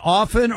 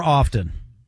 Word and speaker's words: often: Do you use the T? Do you say often? – Often. – By the way, if you look in, it says there's no mispronunciation of the word. If you say often and leave the T off, that's fine.